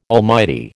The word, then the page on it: almighty: American English